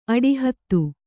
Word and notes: ಅಡಿಹತ್ತು: aḍihattu

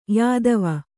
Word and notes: ಯೌತವ: yautava; Kannada

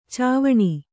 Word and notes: ಚಾವಣಿ: cāvaṇi

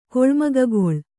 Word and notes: ಕೊಳ್ಮಗಗೊಳ್: Kannada

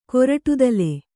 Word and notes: ಕೊರಟುದಲೆ: koraṭudale

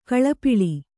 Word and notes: ಗಾಲಿಪಲ್: gāli pal

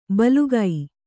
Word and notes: ಭೀಲುಕ: Kannada

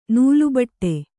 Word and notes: ನೆಲೆಬೀಡು: nele bīḍu

